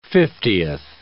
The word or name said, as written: fiftieth